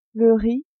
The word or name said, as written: riz